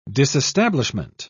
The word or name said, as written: disestablishment